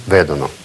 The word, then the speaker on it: vedono: «И loro vedono». То есть ударение опять-таки вот сюда, на корень. «Loro vedono».